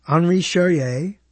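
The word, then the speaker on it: Henri Charrière: The original story of the only man to escape from Devil's Island, Henri Charrier. I think I said his name correctly. I know I did.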